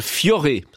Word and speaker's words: Fiore: Le chanteur ticinois Valentino Vivace avec son dernier single Fiore, ça se prononce comme ça, sur RTS Première.